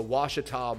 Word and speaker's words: Ouachita: And since 1871, we've bottled it right here in a remote valley of Ochita, Ouachita Mountains, U.S. Uh, uh, Ochita. Oh no, no, the the the Ouachita Mountains. That's how you say it.